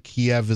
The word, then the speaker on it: Kyiv: Apparently, I was wrong last hour when you said Kyiv, and I corrected you to Kiev. It appears that Kiev is the Russian pronunciation, and Kyiv is the Ukrainian.